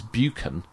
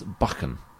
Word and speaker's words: Buchan: Apparently, what I was saying as Buchan should be pronounced as Buchan. – Okay. – Or Buchan. So it's a hard K.